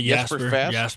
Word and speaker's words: Jesper Fast: Jesper Fast, did I say that right? – Yeah, Jesper Fast. – Jesper Fast.